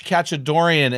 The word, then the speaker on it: Khachadourian: Hey guys, I'm really excited to introduce our guest on the show today, Josh Khachadourian. And yes, I did pronounce that right.